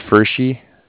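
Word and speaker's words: Pferschy: Yeah, it's Fershi.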